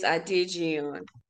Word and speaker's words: Adejiyan: It's Adejiyan.